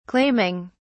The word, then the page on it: claiming: British English